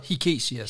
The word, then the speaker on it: Hikesias: H-I-C-E-S. – Det er noget med, at det er, når vi udtaler det herhjemme, så er det noget græske navne, der skal du lægge tryk på anden stavelse. Det hedder Hikesias.